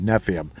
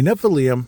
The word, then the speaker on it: Nephilim: And I'm finally saying it correctly. There were times I called it Nephium. A Nephilim is,